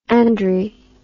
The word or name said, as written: Andry